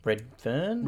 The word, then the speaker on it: Redfern: I think it's Renfield. Am I saying it right? – Redfern? – Redfern, sorry.